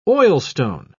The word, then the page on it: oilstone: British English